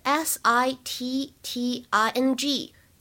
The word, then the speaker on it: sitting: 但是如果你们练的是 American accent，如果是练美式的发音的话呢，要注意一下当中有美音浊化的现象，这个 t 的发音呢会稍微偏向的一点，所以呢就会读成 sitting，sitting。